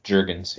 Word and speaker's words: Jurgens: Cam Jurgens placed on IR. Is that how you say his name? Jurgens. – Jurgens, yeah.